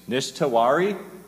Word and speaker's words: Nish Tewari: Nish Tewari. Am I saying that right, Nish? – Is that right? – That's the best I've ever heard.